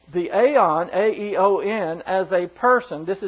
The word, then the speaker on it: aion: I worked on this today, and the word is aeon, A-I-O-N. In the Greek, it's pronounced aeon, and it's a noun.